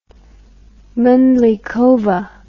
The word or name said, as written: mandlikova